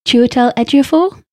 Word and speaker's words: Chiwetel Ejiofor: Well, I've gone for Brody, I've gone for, I'm going to try and pronounce his name correctly, Chiwetel Ejiofor? – Yes.